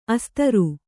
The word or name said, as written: ಅಸ್ತ್ರಿ